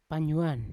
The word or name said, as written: panyuan